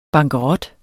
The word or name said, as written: bankerot